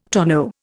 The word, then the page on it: tonneau: Indian